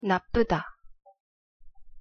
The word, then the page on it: 나쁘다: Korean